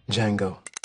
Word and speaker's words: Django: Django. The D is silent.